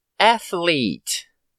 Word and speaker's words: athlete: Isso acontece devido à pronúncia do TH junto com o L, que fica athlete. Lembra mais aflito do que atleta, certo? Mas é atleta.